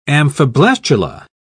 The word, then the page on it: amphiblastula: British English